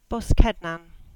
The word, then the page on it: Boskednan: English